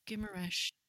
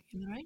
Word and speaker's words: Guimarães: We're quite interested in the whole country, but I was looking at, and I would love for you to correct my pronunciation. Is it Guimarães? – Guimarães. – Okay, Guimarães.